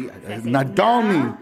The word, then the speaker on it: Nadolny: It's Stephanie Nadolny. See, Nadolny. You see, doll, and then your knee. – Nadolny. There you go.